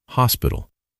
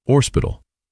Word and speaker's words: hospital: I learned that she had taken Sister Belden to task for the way in which she pronounced hospital. This sister said it should be pronounced orspital, and Sister Belden put the dictionary into her hands.